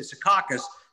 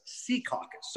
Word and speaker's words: Secaucus: When we first moved here, MLB Network, I used to say Secaucus. Now I know it is pronounced Secaucus.